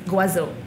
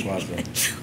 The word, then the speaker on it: Goisot: Guazzo. – Spell. – It's from the Côte d'Azur. Oh, spell it G-O-I-S-O-T. – Okay. – Right? – Guazzo.